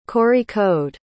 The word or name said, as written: kozhikode